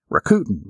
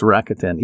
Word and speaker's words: Rakuten: I want to say Rakuten, but that's not the way to pronounce it. It's Rakuten.